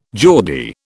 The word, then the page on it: Geordie: British English